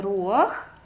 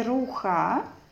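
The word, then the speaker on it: руах (רוח): Руах, это слово читается руах, не руха. Всегда у нас есть правило, когда стоит в конце хет и под ним патух а, то читается не ха, а читается наоборот ах.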